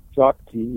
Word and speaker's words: Shakti: Shakti, yeah.